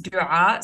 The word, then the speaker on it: Doaa: The nickname D, when I first moved to New York, Doaa, it is actually pronounced Doaa. So there is a letter that's not in the English alphabet. So it was a little bit difficult for people to pronounce.